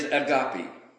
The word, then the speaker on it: agape: And when we think about this, now the word is agape. And it's spelled A in the English, A-G-A-P-E. There's another one that is A-G-P-E-O, which is agapeo.